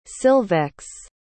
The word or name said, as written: silvex